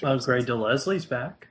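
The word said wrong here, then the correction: Grey DeLisle: Gray DeLisle. It's spelled Leslie, yeah, but it's Gray DeLisle.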